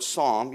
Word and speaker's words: psalm: In the English, the word psalm, which is pronounced with a silent P, you don't pronounce the P, it's just the word psalm.